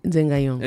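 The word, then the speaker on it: Nzeng-Ayong: Yaya Yobé est dans les bars de Zengayong, c'est comme ça que tu prononces. – Oui, Zengayong.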